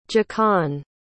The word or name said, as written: jacan